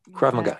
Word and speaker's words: Krav Maga: So your training is, I'm going to say this wrong, Krav Maga. Is that the right way to say that? – Krav Maga, yeah. – Krav Maga. Okay, I did say it right.